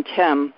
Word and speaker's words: Them: Tim, and that is spelled T-H-E-M, and it is pronounced T-E-M, Tim.